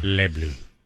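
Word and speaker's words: Les Bleus: The run, the Les Blues, is that right? Anyone? The S is silent. I'm so sorry. – Yeah. – Les Blues.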